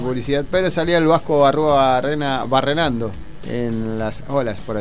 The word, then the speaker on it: Arruabarrena: Arruabarrena. ¿Cómo se dice? – Rodolfo Arruabarrena. – Ahí está. – Barrenando con Arruabarrena.